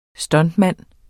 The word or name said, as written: stuntman